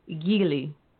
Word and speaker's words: Gilli: Okay, so it's Gilli.